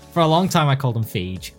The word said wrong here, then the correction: Feige: I've always thought it was Feige, but I've never been sure. – The only pronunciation I've ever heard is actually Feige, so... I think you're right.